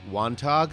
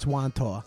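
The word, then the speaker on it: Wantagh: Wontog? – The G is silent. It's Wantaw.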